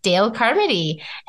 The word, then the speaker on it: Dayle Carmody: Today I am here with Dayle Carmody, and am I pronouncing that correctly? – Yep, that's right.